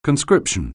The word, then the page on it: conscription: anglais